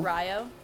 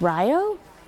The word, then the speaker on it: Rio: Ryo. It's like right by Madison, kind of. It's like 1,000 people. – It's pronounced Rio? – Did you think it was Rio?